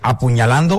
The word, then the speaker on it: apuñalando: Una vez tuve una novia que me estaba, no sé cómo se dice, apuñalando o apuñaleando. – Mire usted, apuñaleando. – Apuñalando. – Apuñalando, me estaba.